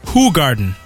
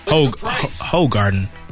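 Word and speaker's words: Hoegaarden: But a dude said it's pronounced who. Who garden. You tell me that don't look like hoe. Tell me it don't look like hoe. That's hoe. Hoe garden.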